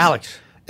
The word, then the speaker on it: Aleks: A-L-E-K-S. He's Canadian. – All right, Alex. – Yeah, Alex. – All right. It's spelled. – There's no X, yeah. – There's no X in the Alex.